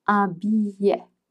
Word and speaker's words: billet: A banknote, un billet. Un billet. Now, the spelling is B-I-L-L-E-T. You make a U sound and you don't pronounce the T.